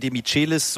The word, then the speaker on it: Demichelis: Martin Demichelis, so will er selber ausgesprochen werden, obwohl es ihm eigentlich relativ egal ist, aber das ist die spanische Aussprache seines argentinischen Namens.